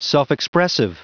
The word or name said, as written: self-expressive